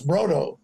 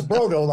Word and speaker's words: Brodow: But it's Brodow, like Frodo.